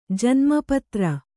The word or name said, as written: ಜನ್ಮ ಪತ್ರ